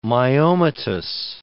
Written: myomatous

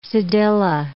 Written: cedilla

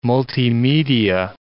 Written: multimedia

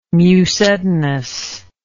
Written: mucedinous

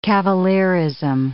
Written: cavalierism

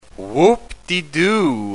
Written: whoop-de-doo

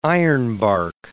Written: ironbark

